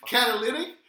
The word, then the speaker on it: catalytic: You know, by the way, it's pronounced Cadillac converter. – Catalytic. – Catalytic converter. – Oh, catalytic. Okay, Jesus.